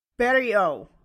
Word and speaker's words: Beria: Berio. – Berio. Oh yeah, somebody sent me this on Twitter. That's like O at the end. – Yeah, the French OT is just pronounced like an O.